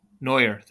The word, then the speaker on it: Neuer: Noir. How do you pronounce his last name? The Germany goalie. Neuer, thank you.